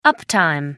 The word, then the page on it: uptime: American English